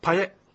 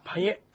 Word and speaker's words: パエ: ですから、実は、パエ。 – パエ。 – 二つしか音ないですね。はい、カタカナで書くと、パーっと書いて、イーと書いて、ちっちゃいエを書いてますけど、パエ。